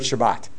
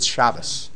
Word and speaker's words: Shabbat: Even in the word Shabbat, like in Ashkenazi, it's Shabbos. – Right. – Where in Sephardic, it's Shabbat.